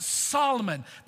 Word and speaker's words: Salmon: His great-great-grandfather was named Solomon, spelled like you spell the fish, salmon. Maybe you pronounce it salmon, I don't know. But I pronounce it Solomon.